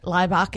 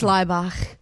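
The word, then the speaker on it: Laibach: Liebach is correct. – Well done, us. So here's some Liebach. Do you reckon McCartney was like, okay. – They're from Slovenia, and it is Liebach. – Liebach.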